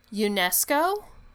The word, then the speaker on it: UNESCO: She was the first member of Hollywood to join UNESCO.